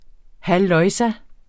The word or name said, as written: halløjsa